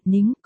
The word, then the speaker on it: Ninh: Ninh là Ninh, tên của Diệp Ninh, phát âm là, nính, còn Linh nghĩa là số không kia thì là Linh, phát âm là, lính. Phát âm khác nhau mỗi L với N thôi mặt cười, Đại Mỹ Ninh nghĩa là Ninh Đại Mỹ Nhân.